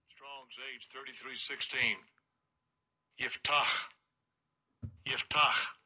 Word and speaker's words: Yiftach: I don't know how you get Yiftah out of that, but I'd always heard it pronounced Yaftah, but that's English, not Hebrew.